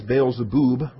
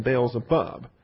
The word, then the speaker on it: Beelzebub: Now, in the Hebrew, from 2 Kings chapter 1, we have Beelzebub with a B-U-B ending. And the shurik is a long oo, so we ought to be pronouncing this Beelzebub rather than Beelzebub.